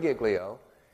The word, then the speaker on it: Giglio: Giglio? Okay, Louis Giglio.